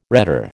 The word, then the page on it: rhetor: American English